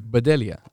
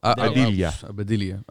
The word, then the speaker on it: Bedelia: Bedelia. – Bedelia.